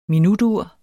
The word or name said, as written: minutur